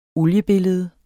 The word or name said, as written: oliebillede